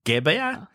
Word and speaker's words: Gebbia: 这两位大学生的名字叫 Brian Chesky 跟 Joe Jabia。 If I pronounce wrong, maybe John can correct my pronunciation. – Don't worry. Yeah, these are not very American names. So Brian Chesky and Joe Gebbia? – Oh, yeah.